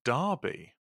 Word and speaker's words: Derby: I'm from Derby. – Which is pronounced Derby.